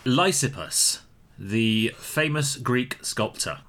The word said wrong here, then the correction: Lysippus: I think it's Lysippus. – Is it Lysippus? It is Lysippus. – Yeah, you put the emphasis slightly incorrectly, but that's okay.